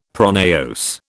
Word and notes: pronaos: British English

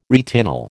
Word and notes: retinol: American English

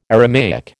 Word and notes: Aramaic: American English

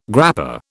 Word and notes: grappa: British English

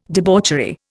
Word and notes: debauchery: Indian

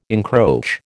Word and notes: encroach: American English

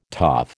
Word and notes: toff: American English

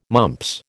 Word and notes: mumps: American English

